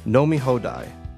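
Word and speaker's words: No, mi hodai (飲み放題): The Japanese even have a special karaoke password, no mi hodai. It's pronounced kind of like an Irish pimp's lament. No, mi hodai.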